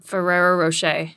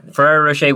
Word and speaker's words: Ferrero Rocher: Yeah, and so how do you say that? – Ferrero Rocher. – Okay, Ferrero Rocher, which creates Nutella.